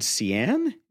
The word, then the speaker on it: Ceann: Has anyone ever recommended the band Cian? Is that how you pronounce that? C-E-A-N-N. – Yeah.